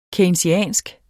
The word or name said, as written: keynesiansk